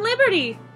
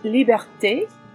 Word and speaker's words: Liberté: It's Madame Liberty! – It's pronounced Liberté.